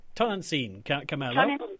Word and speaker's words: Tonantzin Carmelo: Pronunciation might be a little bit off base for this, really. Tonantzin Carmelo? Or Tonantzin Carmelo? – Yes, Tonantzin. Tonantzin Carmelo.